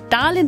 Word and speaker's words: Darlin: Og nu havde vi jo ellers lige fået styr på, at Darlin, det er ligesom at sige darling på engelsk, det er sådan, det skal udtales bare uden givet til sidst.